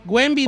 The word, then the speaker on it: Wembanyama: Wembe, dile Wembe, como le dice todo el mundo.